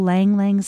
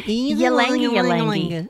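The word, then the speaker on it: ylang-ylang: I thought it was Ylang Ylang. – I told you that. – You were right.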